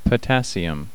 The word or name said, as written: potassium